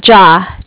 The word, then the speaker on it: Jia: J-I-A. – J-I-A. How do you say that? J-A? – Ja.